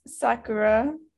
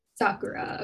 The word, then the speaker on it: Sakura: And on this bus, he meets Sakura. Am I saying that right? Okay. – Oh yeah, Right. – according to the audiobook, it's Sakura. – Sakura.